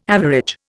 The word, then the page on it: average: Indian